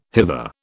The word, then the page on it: hither: British English